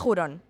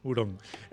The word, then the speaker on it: hurón: que no seas jurón, total. – Jurón. Se escribe con H, pero veo que se pronuncia con, J. Hurón.